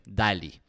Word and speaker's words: DALL-E: Me imagino que lo han visto, si no, se escribe DALL-E, así como WALL-E, pero con D, DALL-E. Y el nombre es un acrónimo de mezcla de entre WALL-E y Salvador Dalí.